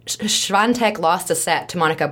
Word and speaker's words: Świątek: We have Iga Świątek. I don't know where the N is in that name, but apparently that's how you pronounce it.